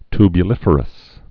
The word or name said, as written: tubuliferous